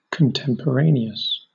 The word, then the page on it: contemporaneous: British English